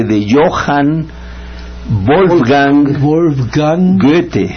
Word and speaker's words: Goethe: Goethe. – Ya, yeah, bien. – Goethe, pero siempre lo hemos dicho, así. No tienes que decirnos bien. – Goethe.